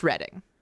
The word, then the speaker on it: Reading: It's spelled like Reading, but it's pronounced Reading. – Oh, our Reading is R-E-D-D-I-N-G. – This is R-E-A-D-I-N-G. – That's weird. – Yeah, it's English. It's spelled Reading, pronounced Reading, go with it.